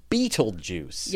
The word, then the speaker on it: Betelgeuse: And it, of course, the hilarious thing is how people give us such a hard time because they're expecting that it should be Beetlejuice.